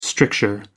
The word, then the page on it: stricture: American English